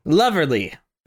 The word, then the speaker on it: Loverly: I would end it be lovely, lovely. – What do you think about Wouldn't It Be Lovely? – Are you saying that right? Is it Loverly? – Loverly.